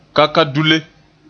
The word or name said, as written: kakadule